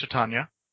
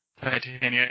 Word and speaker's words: Titania: Shakespeare has had characters with that name, and in the British it is pronounced Titania. However, Titania is a perfectly acceptable pronunciation as well. American English. – America.